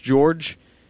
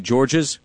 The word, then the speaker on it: George: So with his French background, the S is silent. David also told another media member recently that it's George's because he's here in America. – Correct. – And that here the pronunciation with the S would make more sense. – Yes. – So my guess is over the next several years you will hear both. To George, either appears to be fine. He did say his mom would not like the S being pronounced.